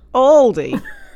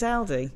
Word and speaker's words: Aldi: which would be Aldi, wouldn't it? – Yeah.